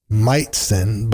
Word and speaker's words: Majcen: Meitzen, but it's spelt a little differently. M-A-J-C-E-N is how you spell it, but according to Google, you pronounce it Meitzen.